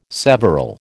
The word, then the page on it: several: American English